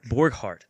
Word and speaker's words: Borghardt: Borghardt.